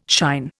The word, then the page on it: shine: Indian